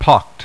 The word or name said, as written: pocked